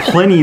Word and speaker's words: Pliny: So Pliny the Elder is how it's pronounced.